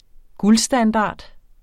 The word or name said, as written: guldstandard